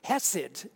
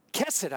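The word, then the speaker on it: kesed (חסד): Indeed, those who love kindness or mercy, as it oftentimes is translated hesed in the kesed, I should say. It's a hard sound at the beginning.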